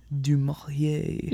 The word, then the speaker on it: du Maurier: written by Daphne Dumour. – Dumourier. – Yes, thank you.